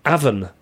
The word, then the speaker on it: Avon: By the way, I loved how you pronounced Avondale, the way you say Avon in England. We say Avon, like the A in Gatwick.